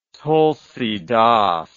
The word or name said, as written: tulsidas